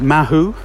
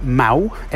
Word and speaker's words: Mahou: Uh, some people will mispronounce it Mahu, um, but uh, the locals pronounce it Mau, M A H O U, and uh, it's the most common uh, beer to find, as I say, in the centre of Spain, certainly Madrid.